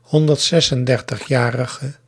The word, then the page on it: 136-jarige: ˌhɔndərtˈsɛsənˌdɛrtəxˌjarəɣə; 9 lettergrepen; 136-·ja·ri·ge